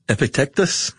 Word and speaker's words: Epictetus: Uh, well, I'm going to pronounce it Ep- Epic Epictet- Epictetus.